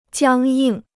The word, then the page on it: 僵硬: jiāng yìng